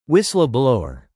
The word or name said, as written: whistleblower